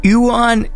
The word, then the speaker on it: Euan: Yuan. Okay, so Yuan. Just for everyone knows, it's spelled E-U-A-N.